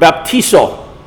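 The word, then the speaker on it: baptizo (βαπτίζω): The word baptism there, and the word baptism used almost every other place in the New Testament, comes from the Greek word baptizo. It's spelled just like it sounds, B-A-P-T-I-Z-O.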